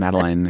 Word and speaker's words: Madeleine: His Madeleine. Is that how you pronounce that? Madeleine? – Madeleine.